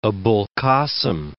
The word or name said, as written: Abul Kasim